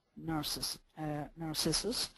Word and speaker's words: Narcissus: Poor Echo falls in love with Narcissus. And am I saying that right? It doesn't sound right. – No, you are. You are.